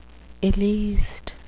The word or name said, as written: illeist